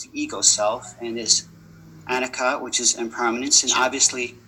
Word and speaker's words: Anicca: Is it Anicca or is it Anicca? I heard it pronounced two different ways. So how do we? – You referring to impermanence or not self? – Yeah. No, impermanence. – Is Anicca.